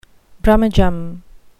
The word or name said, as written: brummagem